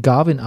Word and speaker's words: Gawain: König Arthur spricht ihn Garwin aus.